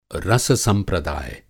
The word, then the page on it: रस-संप्रदाय: Hindi